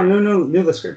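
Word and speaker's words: New Liskeard: But Kristen out of New Liskard, Ontario, Maybe even I screwed up the town in Ontario. – No, New Liskert, that's what it's called. – New Liskert, okay.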